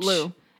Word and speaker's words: Lugh: Gods, Apollo, Horus, Lugh, L-U-G-H. – Lou.